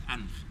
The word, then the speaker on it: Ankh: Well, this is the sign we pronounce as in hieroglyphic language as Ankh, A-N-K-H, Ankh.